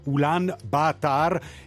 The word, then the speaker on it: Ulaanbaatar: Allora, la pronuncia andava bene, prima cosa? – Sì, andava abbastanza bene. Se uno deve essere proprio giusto giusto col mongolo, praticamente loro mangiano tutte le vocali, solo leggono quando sono doppie, quindi è Ulaanbaatar.